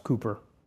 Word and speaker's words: Cowper: One of my favorite hymn writers is William Cowper, spelled Cowper but pronounced Cooper, well acquainted with grief and suffering.